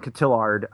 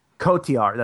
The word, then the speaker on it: Cotillard: Michael Fassbender and Marion Cotillard. I think I butchered that Cotillard. – Name. But you know, Cotillard, that's right.